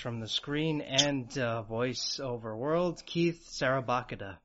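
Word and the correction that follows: Szarabajka: Sarabika. – Sarabika, sorry. you want to be Polish, it'd be Shadabika.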